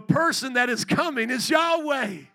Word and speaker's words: Yahweh: Now run that mouse over the Lord there and you'll see the divine name of God, Yohevah in Hebrew, pronounced as Yahweh, also Jehovah.